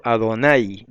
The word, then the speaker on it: Adonai: Por ejemplo, Adonai lleva el acento en la A, no es en la I.